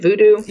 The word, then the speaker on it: Vudu: Is that how you say it? Vudu? – Yeah, V-U-D-U.